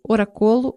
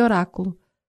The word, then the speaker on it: oráculo: O professor, um tal Gerace, um homem dos seus 60 anos, indolente, sempre a bocejar ruidosamente, desatou a rir assim que eu pronunciei oracolo em vez de oráculo.